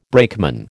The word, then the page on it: brakeman: American English